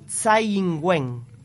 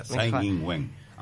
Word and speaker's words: Tsai Ing-wen: Bueno, acá en Taiwán, vos sabrás cómo se pronuncia, pero es Tsai Ing-wen, ¿no? – Sí, lo hiciste así. Bárbaro. ¿Viste? Mejor que Ángela, Tsai Ing-wen.